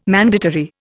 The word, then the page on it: mandatory: Indian